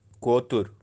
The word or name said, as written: kotur